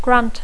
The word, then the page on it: grant: English